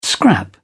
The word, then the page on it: scrap: British English